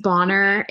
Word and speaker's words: Bonar: No, but it's pronounced Bonar. It's like Bonar. It's French, okay. So I guess it probably is Bonar, like if I was French and cool, but, you know, America, we just ruin everything.